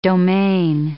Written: domaine

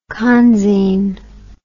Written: conzine

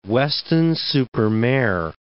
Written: Weston-super-Mare

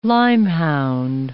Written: lyme-hound

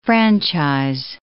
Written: franchise

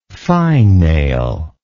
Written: fine nail

